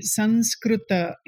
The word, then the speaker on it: Sanskrita: Yeah, I mean, there is a lot of change in the pronunciation, but so Sanskrita or Sanskrita, you know, you will hear this. So Sanskriti, Sanskrita is the right pronunciation.